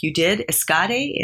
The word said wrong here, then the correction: ESADE: am I pronouncing it correctly, in Barcelona? – ESADE, E-S-A-D-E.